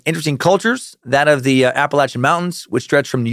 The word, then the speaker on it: Appalachian: And among many other Appalachian cryptids, they're what we're covering this next week. And yes, I do prefer that pronunciation over Appalachian.